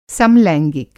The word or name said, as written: समलैंगिक